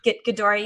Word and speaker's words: Ghidorah: You know, where he ends up Monster Zero, or how do you say his name? – Yeah. King Ghidorah. – Ghidorah.